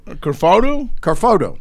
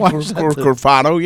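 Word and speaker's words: Cafardo: Nick Carfado? – Carfado. – Yeah, okay. – Is that the right pronunciation? – I believe Carfado, yeah. – Yeah, Carfado.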